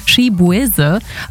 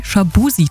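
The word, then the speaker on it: Chibueze: Die Leute, die haben seinen Nachnamen damals immer falsch ausgesprochen. Sein bürgerlicher Name ist nämlich eigentlich Shibuese, aber oft haben sie irgendwie Shabusi draus gemacht und dann hat er sich einfach entschlossen, das auch zu seinem Künstlernamen zu machen.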